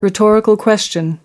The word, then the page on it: rhetorical question: American English